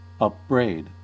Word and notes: upbraid: American English

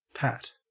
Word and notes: pat: Australian English

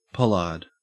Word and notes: pollard: Australian English

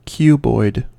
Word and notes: cuboid: American English

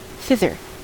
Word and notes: thither: American English

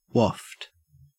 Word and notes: waft: Australian English

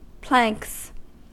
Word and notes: planks: American English

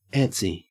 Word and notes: antsy: Australian English

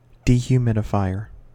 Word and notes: dehumidifier: American English

